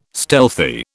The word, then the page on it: stealthy: British English